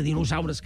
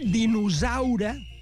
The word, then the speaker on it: dinosaure: S'ha dit dinosaure i això està mal pronunciat. Se pronuncia dinosaure. És sorda, dinosaure.